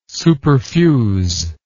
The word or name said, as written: superfuse